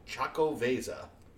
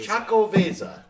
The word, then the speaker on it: Xocoveza: A really, really tasty, incredibly tasty beer called Chaco Vesa. And it's spelled Xocoveza. – The Chaco Vesa. – Chaco Vesa.